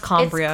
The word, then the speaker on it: Cambria: It's pronounced Cambria.